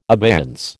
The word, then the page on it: abeyance: American English